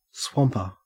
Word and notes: swamper: Australian English

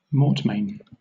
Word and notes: mortmain: British English

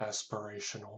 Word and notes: aspirational: American English

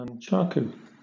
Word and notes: nunchaku: British English

